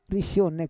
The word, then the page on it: risionek: Roon